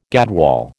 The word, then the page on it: gadwall: American English